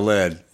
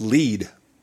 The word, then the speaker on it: Lead: Because we said we were going to Lead. – Yeah. – It's not how it's pronounced. – Really? – Nope. It's pronounced Lead.